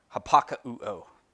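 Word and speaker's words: hapakauo (ὑπακούω): And here it's that same word, hapakauo, is uh, essentially how it's pronounced.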